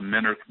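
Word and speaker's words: Minirth: Is it Minerth or Minerth, or how do I pronounce that? – Yeah, it's called the Minerth Meyer Clinic.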